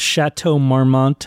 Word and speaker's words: Chateau Marmont: Anyhow, we are in Hollywood, in the heart of Hollywood, at the Chateau Marmont Hotel.